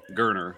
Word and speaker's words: Gurner: I'm sorry, let me pronounce his name correctly. Gurner, like Turner with a G.